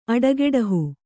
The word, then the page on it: ಅಡೆಗೆಡಹು: aḍegeḍahu